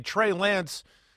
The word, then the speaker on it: Trey Lance: I mean, hey, I mean, Trey Lance, you're saying it right.